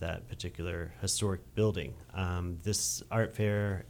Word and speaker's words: Art Fare: What is Art Fair? – So Art Fair, A-R-T-F-A-R-E, because the spelling or the pronunciation can be confusing.